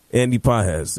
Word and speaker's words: Pages: How do they say his name? – Pages. – Pages? – Andy Pages. – Andy Pages. It's another home run. – Spelled like Pages. – Yeah, well, it's the accent.